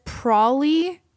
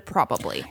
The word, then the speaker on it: probably: Says Prawley instead of probably.